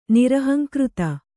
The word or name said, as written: ನಿರಹಂಕೃತ